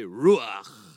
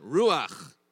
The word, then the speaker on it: Ruach: Everyone say Ruach. If you're not spitting on the person in front of you, you're not saying it right. Try again. Ruach.